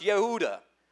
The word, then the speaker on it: Yehuda: But the name Judah is pronounced Yehuda. So it's Judah and Yehuda.